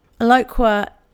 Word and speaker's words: Eloqua: Eloqua, which is spelt E-L-O-Q-U-A,